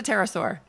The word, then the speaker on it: pterosaur: It's a pterosaur.